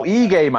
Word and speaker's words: Ige: Dan Oig against Kader. – No, Ige, man.